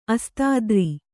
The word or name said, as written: ಅಸ್ತಾದ್ರಿ